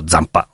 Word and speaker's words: Zampa: diventa pirata ma torna in patria con il nome di appunto Zampa, come si dovrebbe pronunciare alla francese.